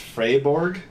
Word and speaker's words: Freiburg: Like, that is actually inspired by a real place in Germany, in Freiburg, or I believe it's pronounced Freiburg.